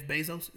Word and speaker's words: Bezos: Like somebody posted the other day, I was looking at something that Jeff Bezos, is that, am I saying it right? – Yeah.